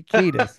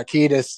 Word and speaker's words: Kiedis: And then, like, didn't know it was Anthony Kiedis. That's how you pronounce it, right? – Yeah, and I think so. Yeah, Kiedis.